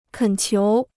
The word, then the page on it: 恳求: kěn qiú